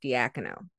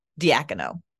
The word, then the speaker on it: Diacono: With that open A, but yeah, I was saying Diacono before, but I think it's Diacono. – Yeah, I saw one of those, like, there's a YouTube channel of like how to pronounce NBA players' names, and they said, yeah, like yak, as in like the animal, Diacono.